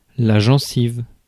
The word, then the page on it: gencive: France; [ʒɑ̃.siv]